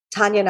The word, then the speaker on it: Taniya: So my Instagram is just my name at Tanya Nayak, which is spelled T-A-N-I-Y-A. And my last name, Nayak, N-A-Y-A-K.